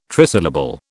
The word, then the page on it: trisyllable: British English